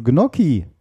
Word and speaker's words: Gnocchi: Wörter mit CCH werden auf Italienisch tatsächlich grundsätzlich als K-Laut ausgesprochen, also Gnocchi.